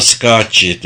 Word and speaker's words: Scacchi: Greta Scacchi dedi Chili. İşte o, adı böyle telaffuz ediliyor. Scacchi, hiç bilmiyordum. Scacchi diye duydum ama Scacchi diye hiç duymamıştım.